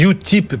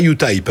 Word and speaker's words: Utip: Alors, on dit Utip, pas Utype, hein, Utip. – On dit Utip, oui. – Ça s'écrit, voilà, ça s'écrit U-T-I-P.